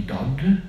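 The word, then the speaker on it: dod (דוד): to oblubieniec albo umiłowany, ukochany w języku hebrajskim brzmi dod, a pisze się D-W-D, czyli tak samo jak imię Dawida. Tylko to W z odpowiednim znakiem punkcyjnym czyta się wtedy jako O, czyli o Mesjasz będzie pochodził z domu Dawida i to mamy taką aluzję, że on jest oblubieńcem.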